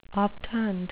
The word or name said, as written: obtund